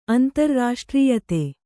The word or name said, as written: ಆಂಧ್ರ